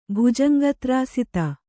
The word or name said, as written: ಭುಜಂಗತ್ರಾಸಿತ